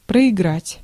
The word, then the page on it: проиграть: /prəɪɡˈratʲ/; proigrat